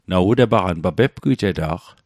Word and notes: pepgwijeta'q: Mi'gmaq

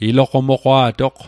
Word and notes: iloqomoqwa'toq: Mi'gmaq

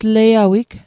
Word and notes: tleiawig: Mi'gmaq

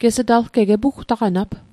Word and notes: epugtaqanat: Mi'gmaq

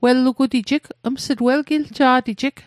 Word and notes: welgiljet: Mi'gmaq

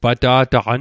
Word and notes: pata'taqan: Mi'gmaq

